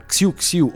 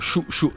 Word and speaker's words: Xiu Xiu: A eseguirla sono Xiu Xiu o Shu Shu, dipende un po' da quanto siete snob con la pronuncia,